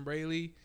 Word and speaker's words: Brailey: Just type in on Christian Braley. Last name B-R-A-I-L-E-Y.